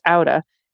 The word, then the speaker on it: Atta: It's spelled ATTA, pronounced Auta, and it is stunningly beautiful.